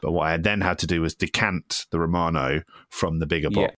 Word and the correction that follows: Romana: Romana. – It's Romana. We can't let this go more than six times. But yeah, it's Romana. We can't let you say it wrong six times in a row.